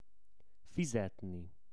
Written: fizetni

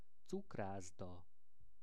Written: cukraszda

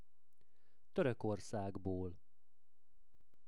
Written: Torokorszagbol

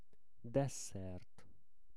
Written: desszert

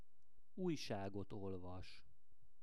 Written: ujsagot olvas

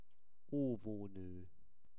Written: ovono

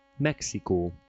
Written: Mexiko

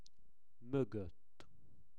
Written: mogott

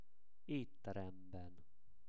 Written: etteremben

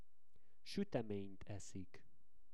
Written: sutemenyt eszik

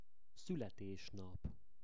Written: szuletesnap

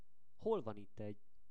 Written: Hol van itt egy

